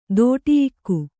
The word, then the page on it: ದೋಟಿಯಿಕ್ಕು: Kannada